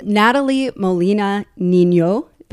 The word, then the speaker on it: Natalie Molina Nino: I'm going to like fangirl out on you for a second, but I'm here with Natalie Molina Nino. Am I pronouncing that right? – Better than most. In fact, better than 99% of most.